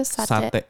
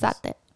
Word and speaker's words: Satay: Satay heißt es. – Satay.